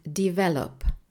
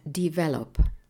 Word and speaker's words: develop: Kiejtése tehát develop. Jelentése fejleszteni, fejlődni. Fontos megjegyezni, hogy ebben az igében a hangsúly a második szótagon van, ezért azt egy picit erősebben nyomjuk meg, tehát develop.